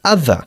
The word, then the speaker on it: other: Are they teaching each other? Ten mucho cuidado con la pronunciación de la palabra other. No se dice other, sino other. – Other. – Other. Bajando un poquito la mandíbula.